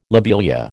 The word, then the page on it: lobelia: American English